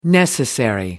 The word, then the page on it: necessary: American English